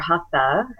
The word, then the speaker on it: hatha: And what I mean by that is hatha, or hatha, as we might be used to pronouncing it, can translate to mean forceful or effortful.